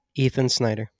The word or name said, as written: Ethan Snyder